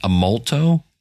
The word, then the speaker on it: Amolto: I also heard about, I believe it's pronounced Amolto, A-M-O-L-T-O, from Matthew Passy, from Pod to Pod.